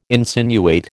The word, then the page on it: insinuate: American English